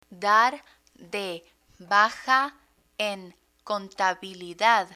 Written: dar de baja en contabilidad

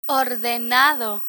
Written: ordenado

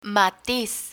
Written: matiz

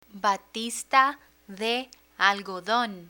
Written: batista de algodon

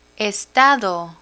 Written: estado

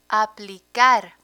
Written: aplicar

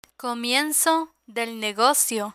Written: comienzo del negocio

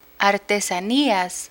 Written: artesanias

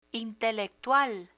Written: intelectual

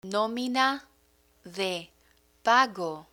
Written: nómina de pago

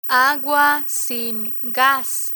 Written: agua sin gas

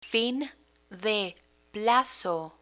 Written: fin de plazo

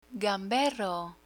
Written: gamberro

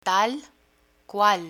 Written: tal cual

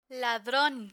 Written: ladron

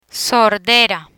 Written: sordera

